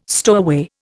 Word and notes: stowaway: Indian

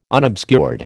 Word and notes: unobscured: American English

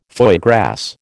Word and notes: foie gras: American English

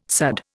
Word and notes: sudd: Indian

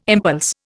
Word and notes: impulse: Indian

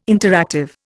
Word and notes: interactive: Indian

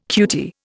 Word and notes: cutie: Indian

